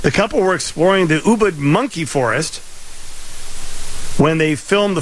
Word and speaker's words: Ubud: Ubud. – It's pronounced it, Ubud. – It actually is. It's pronounced Ubud. I've been there.